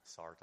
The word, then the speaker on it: Sartre: There's a, I think who I think it was John Paul Sartre was uh, Sartre, I'm French pronunciation.